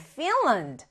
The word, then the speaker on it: Finland: 첫 번째, Finland. 자, Finland라고 읽지 않아요. 그래서 Finland 해서 land의 강세가 잘안 들려요. 그래서 앞에 있는 fin이 좀 강조되고, 우리 말처럼 핀란드라고 읽지 않는다.